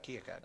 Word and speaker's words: Keokuk: Here's an interesting story I found, that as folks begin to settle in down on the river, the chief of them, I say Keokuk, am I saying that right? – That's right, Keokuk.